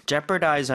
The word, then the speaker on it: jeopardize: So, jeopardize 하는 거 중간에 어우는 발음을 하지 않습니다.